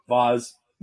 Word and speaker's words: Vase: So it's spelled V-A-S-E. – So Vase? – Nope, Vaz. But it's spelled Vase, yes.